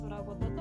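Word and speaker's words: 宙ごと: 宇宙の宙に言葉のことと書いて空ごとと読みます。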